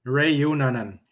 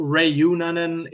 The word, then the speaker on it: Reunanen: It's almost like you roll your R's. – Yeah, it's. I guess it's a European thing. – But I can't roll my R's for my life. – No. So Reunanen is close enough.